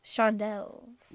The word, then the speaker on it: Shondells: Shondells.